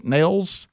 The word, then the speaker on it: Nehls: It is N E H L S Nails.